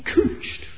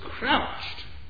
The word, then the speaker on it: couched: He stooped down, he couched as a lion. I say couched, you can pronounce it couched, but I get the impression that most people reading this think the word is crouched. It isn't.